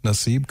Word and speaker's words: Nassib: Nassib, is that how you pronounce his last name? – Nassib.